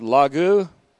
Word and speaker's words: Logou: And there is an element of the Word of God, the general Word of God, called Logou, L-O-G-O-U, Dikaiosune, D-I-K-A-I-O-S-U-N-E, long E, Dikaiosune, Logou, Dikaiosune.